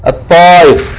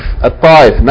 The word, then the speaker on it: Taif: This word, when you see it in English, T-A-I-F, might be deceptive. But it is pronounced, as you see clearly in Arabic, what? At-Taif. At-Taif, not Taif. Years ago we used to say, I'm going to Taif. No such place as Taif. It's Taif. Taif.